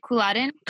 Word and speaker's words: Culloden: Okay, so on that note, staying in Inverness, you mentioned going to Culloden Battlefield. – Right. – Is that how you say it? Culloden? – Culloden, yeah.